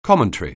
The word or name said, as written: commentary